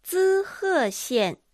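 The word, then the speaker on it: 滋贺县: 滋賀县滋賀县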